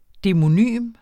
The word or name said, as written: demonym